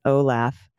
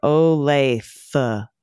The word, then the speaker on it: Olathe: The pronunciation isn't Olaf. Like is not Olaf like laugh. It's rather Olathe. Olathe.